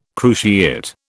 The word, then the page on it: cruciate: British English